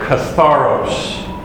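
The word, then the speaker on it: Katharos (καθαρός): K-A-T-H-A-R-O-S. Katharos. If you start thinking about the pronunciation of that word, what would be a word that you've heard a bit that... – Catharsis. – Catharsis. Exactly. And that actually, catharsis comes from the word katharos.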